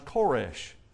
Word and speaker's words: Koresh: Now, it's interesting to note that Cyrus's name in Hebrew is pronounced Koresh.